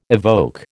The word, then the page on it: evoke: American English